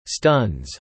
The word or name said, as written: stuns